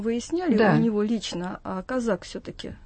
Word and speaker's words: Козак: Хотя, по-моему, президент все-таки делает ударение на втором слоге фамилии Козак. Казак он его вроде бы вчера назвал. Все мы путаемся, но мы будем, наверное, я по инерции Козак буду говорить.